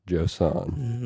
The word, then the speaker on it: Josan: Josan.